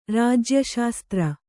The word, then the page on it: ರಾಜ್ಯಶಾಸ್ತ್ರ: Kannada